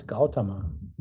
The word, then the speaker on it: Gautama: So, and then the Lao Dan, the Dan part in modern Mandarin pronunciation, that's modern Mandarin pronunciation of both, that was pronounced Dam. So then it's Gao Dam. And there was a final ah after that M, a short one. So it's Gautama.